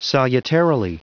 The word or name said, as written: salutarily